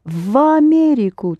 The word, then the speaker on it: в Америку: Yes, Америка starts with а, so you pronounce в Америку together. The в is pronounced as the в.